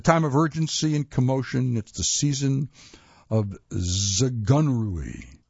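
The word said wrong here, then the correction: Zugunruhe: Well, didn't quite pronounce it right. They tell me in the next sentence how to do it. What a delicious word this is! Zakgunruha. Zakgunruha. It might not gently roll off the tongue, but its meaning is just as beguiling as the funky pronunciation.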